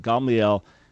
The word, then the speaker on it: Gamaliel: So we normally pronounce it Gamaliel in English, but you had it right in Hebrew. Gamaliel, God is my portion.